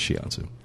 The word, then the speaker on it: shiatsu: It's not pronounced that way, but we'll hear people call it shitsu massages. – That's actually where they roll the dog up. – Yeah, they do. – The dog pads across your back. – But the proper pronunciation is shiatsu.